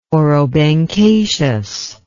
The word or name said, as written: orobanchaceous